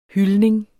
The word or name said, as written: hyldning